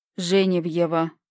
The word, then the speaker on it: Женевьева: Женевьева, поправил Егорыч. – Он и сказал-то с ударением на первом звуке.